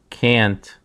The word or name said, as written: cant